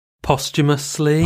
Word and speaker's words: posthumously: How do you say that? – Oh, posthumously.